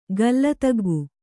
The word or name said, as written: ಗಲ್ಲತಗ್ಗು